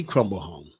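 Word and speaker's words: Cromblehome: Haley Crumble home.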